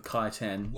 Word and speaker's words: Kytan: No, it's called the Kytan like, Ravager.